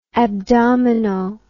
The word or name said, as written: abdomino